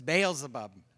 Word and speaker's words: Beelzebub: I looked up how to say that word because I wanted to make sure I said it right. It's Beelzebub.